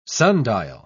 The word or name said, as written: sundial